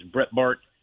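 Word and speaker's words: Breitbart: One of the is Brett Bart, is how you pronounce it.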